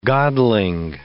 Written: godling

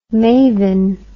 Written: mayvin

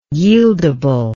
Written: yieldable

